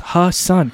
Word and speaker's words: Hassan: And it's not really even that hard to pronounce. Hassan.